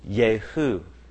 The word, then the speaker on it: Jehu: It looks like Jehu. But the stress is on the last syllable. Jehu.